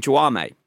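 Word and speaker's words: Jaume: But, so watching all the behind the scenes, they call him Juame. – Juame. – Yeah, which is not how you would think the Spanish would be pronounced.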